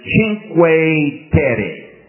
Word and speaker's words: Cinque Terre: the way they pronounce it in Italian, is Cinque Terre.